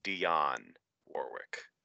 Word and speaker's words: Dionne Warwick: It is Dionne Warwick.